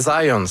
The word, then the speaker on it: Zajonc: Non, c'est Zajonc. – Oui? OK. – J'ai-tu pas dit Zajonc? – Vous avez dit plus Zajonc, mais... – J'ai prononcé un petit peu à l'anglais. J'ai prononcé mon polonais à l'anglais.